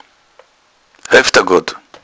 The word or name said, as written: hoefdagodd